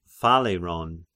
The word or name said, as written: Phaleron